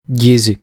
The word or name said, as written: Gyzi